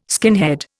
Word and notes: skinhead: Indian

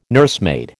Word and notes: nursemaid: American English

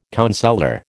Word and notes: counsellor: American English

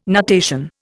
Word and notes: nutation: Indian

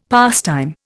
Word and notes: pastime: Indian

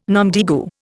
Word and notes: nom de guerre: Indian

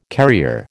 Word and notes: carrier: American English

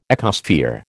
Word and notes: ecosphere: American English

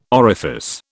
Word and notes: orifice: British English

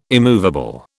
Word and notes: immovable: British English

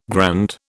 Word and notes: grand: British English

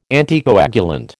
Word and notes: anticoagulant: American English

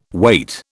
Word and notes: wait: British English

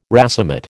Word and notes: racemate: American English

